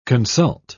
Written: consult

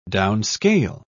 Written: downscale

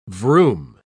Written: vroom

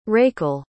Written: Rakel

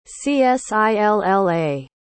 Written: csilla

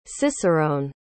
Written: ciceron

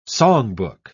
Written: songbook

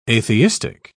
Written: atheistic

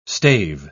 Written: stave

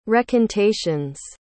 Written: recantations

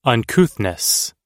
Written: uncouthness

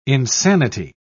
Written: insanity

